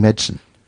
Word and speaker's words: medicine: So medicine, we talked about this last week, written as three syllables, but if you hear someone who's studying it, what are you doing? I'm doing medicine. – Mm. – And that's just two syllables.